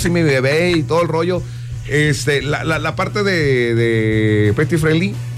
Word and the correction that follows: Pet Friendly: ¿sí se dice así? – Sí, Pet Friendly. – Pet Friendly.